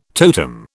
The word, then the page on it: totem: British English